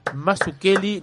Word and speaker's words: Mazzuchelli: Le damos la bienvenida a Isabel Mazukeli, no sé si lo pronuncié bien. Bienvenida Isabel a Encopados. – Bienvenida, Isabel, ¿cómo estás? Muchas gracias muchas gracias por la invitación y lo pronunciaste perfectamente, lo cual es un milagro.